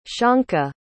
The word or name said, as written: shonka